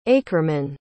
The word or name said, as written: aekerman